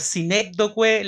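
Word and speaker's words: sinécdoque: Por sinecdoque le decían eh, Sion a Jerusalén. – ¿Cómo se dice? – Sinecdoque. – Sinecdoque. – Sí.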